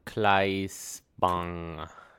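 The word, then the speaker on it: Claes Bang: Cleese Bang.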